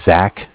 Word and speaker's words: Zach: My name is Zach, spelled Z-A-C-H. – Okay. – And I'm from Cardiff. It's spelled C-A-R as in car, D as in dog, I as in island, F-F as in Frank Frank.